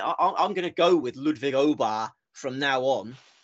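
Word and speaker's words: Åberg: because I discovered last week we've been getting our pronunciation wrong on Ludwig, because I saw him do a piece to camera last week where he said, "Hi, I'm Ludwig Aber."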